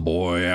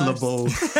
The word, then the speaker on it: Le'Boy: kind of speaks up and says, so I guess we'll let the boy out then. – It's pronounced Le Boy.